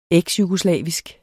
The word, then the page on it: eksjugoslavisk: [ ˈεgs- ]